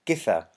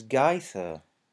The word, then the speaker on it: Githa: I have become used to it being pronounced with a hard G. However, they pronounced it here as Githa. Githa, I think you may be right, yes.